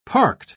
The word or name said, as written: parked